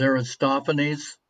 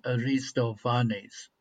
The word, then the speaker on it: Aristophanes: Yeah, I don't quite agree because the great Greek playwright, we know him as Aristophanes, but I had a beautiful Greek girlfriend that corrected me, and she said it's not pronounced that way, it's pronounced Aristophanes.